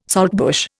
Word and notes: saltbush: Indian

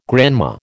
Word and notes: grandma: American English

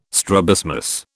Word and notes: strabismus: British English

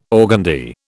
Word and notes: organdie: British English

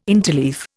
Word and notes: interleaf: Indian

